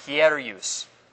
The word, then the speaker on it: hieros: H-I-E-R-O-S. Hierus. This is how you pronounce it.